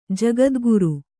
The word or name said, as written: ಜಗದ್ಗುರು